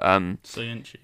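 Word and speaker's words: Söyüncü: I always pronounce it badly, but Soyuncu.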